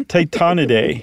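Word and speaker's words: Tytonidae: Titanidae. I added an extra syllable. – Mm-hmm. – Titanidae.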